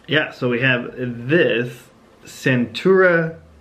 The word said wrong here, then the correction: Stentura: Stenograph. Did I say that right? Yeah. Probably didn't. – Stentura. – Oh, Stentura.